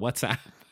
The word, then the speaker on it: WhatsApp: What's why did I say it like that? WhatsApp.